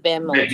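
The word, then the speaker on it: Behemot: No, el, el Ah, el bemot, sí, sí, el bemot. – se pronuncia así.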